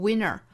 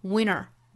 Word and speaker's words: winter: 这种情况其实在口语之中非常多见哈，一般单词也有这样发的，词与词之间连读也有这样发的。比如说冬天这个词读 winter, winter, winter. 那正常我们知道 winter 这个词的发音，对吧？但是其实还有一种发音方法，就是它的音略掉 winter, winter, winter. 这样去读其实就跟 winner 这个词就没办法区分了。